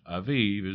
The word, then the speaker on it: Abib: Abib, pronounced Aviv, is the first month of the year.